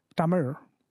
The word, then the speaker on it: Tamizh: They will spell it T-A-M-I-Z-H, and they'll pronounce it Tamir. Like the R sound.